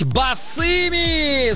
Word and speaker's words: босыми: Ударение в данном слове всегда ставится только лишь на окончание. Поэтому правильно говорить «босыми», Станислав.